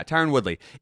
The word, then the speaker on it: Tyron Woodley: Am I saying his name right? Yeah. Tyron Woodley is not a boxer.